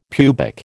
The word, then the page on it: pubic: American English